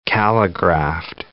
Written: calligraphed

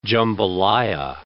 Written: jambalaya

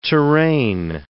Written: terrane

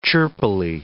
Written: chirpily

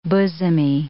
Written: bosomy

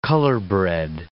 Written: colorbred

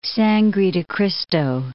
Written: Sangre de Cristo Mountains

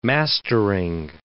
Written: mastering

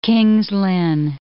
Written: King's Lynn

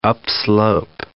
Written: upslope

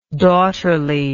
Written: daughterliness

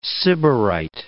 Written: Sybarite

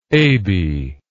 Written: Abie

